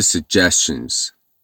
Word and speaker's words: suggestions: I'm open to suggestions. دقت کنین دوستان تلفظ پیشنهاد رو. Suggestions. I'm open to suggestions. حتی بعضی از امریکن ها suggestion هم تلفظ میکنن.